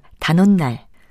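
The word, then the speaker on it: 단옷날: 단어와 수리는 모두 모음으로 끝나고 뒷말인 날의 첫소리 니은 앞에서 니은 소리가 덧나서 각각 단원날, 수린날로 발음되기 때문에 사이시옷이 붙습니다.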